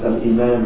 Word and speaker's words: Al-Iman: And I spelled it like this for a reason, so that we can learn how to pronounce it for those of us who have not studied Arabic. We can try and get as close as we can to the way it sounds in Arabic by looking at it spelled like this in English. Al-Iman.